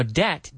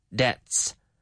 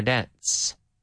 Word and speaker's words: debt: Now, debt, deuda, debt. It has a silent B. We don't say debts. No, but debt, debts. One debt, two debts. That way we could settle our debts.